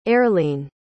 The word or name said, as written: aerlene